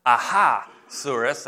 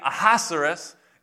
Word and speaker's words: Ahasuerus: And their king, it's kind of hard to pronounce, so I pronounce it Ahasuerus. That's how it helps me remember it. Aha, because it starts off with A-H-A. But that name, Ahasuerus, is a title for the king of Persia.